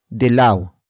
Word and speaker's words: dilaw: Manilaw, which serves as a non-predicate verb, comes from the root word dilaw. Dilaw. Dilaw. Which is spelled as D-I-L-A-W.